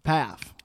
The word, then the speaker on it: Pfaff: First F is silent, so it's like Pfaff. Oh. – Nailed it. Pfaff.